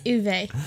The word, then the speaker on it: Uwe: It's Uwe. He's pronouncing it Uwe.